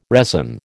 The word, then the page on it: resin: American English